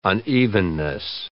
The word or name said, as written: unevenness